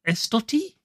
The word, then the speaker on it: Estoty: It's been developed by Estoty.